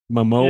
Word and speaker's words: Momoa: See, Jason Momoa. Is that how you pronounce his last name? – Yeah. – Momoa. – Yeah, I think so. Momoa, yeah. – Because I've heard it pronounced like three different ways.